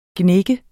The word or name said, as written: gnikke